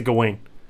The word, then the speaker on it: Gawain: It's such a weird name to pronounce because there's a bunch of people who get like go all over the place, but I pronounce it Gawain.